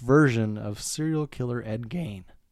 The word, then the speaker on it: Gein: Tomato, tomato, but yeah, I do believe Gein is the correct pronunciation.